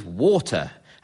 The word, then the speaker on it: Walter: And the Queen's nickname for him, in fact, was Water, as his Devonian accent meant he did not pronounce the L in his first name.